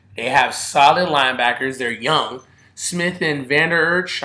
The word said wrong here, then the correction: Vander Esch: I don't think I'm saying his name wrong. – Vander, Esch. sorry.